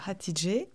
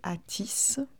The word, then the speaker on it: Hatice: Oui, ça se prononce Hatidje, euh, euh, mais dans ma famille, on a pris l'habitude de, prononcer comme ça s'entend en français, Atis, H-A-T-I-C-E, ça s'écrit,